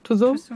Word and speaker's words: Tussauds: Tussauds.